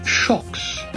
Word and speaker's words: Xocs: Shox is spelled X-O-C-S in the English, but it is pronounced Shox, and they were from Spain.